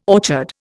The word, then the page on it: orchard: Indian